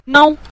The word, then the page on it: nao: nao5